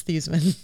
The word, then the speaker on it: Theismann: Notre Dame changed his name to Theismann so it would rhyme with the Heisman for PR purposes. But his name is Joe Theismann.